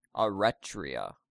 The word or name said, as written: Eretria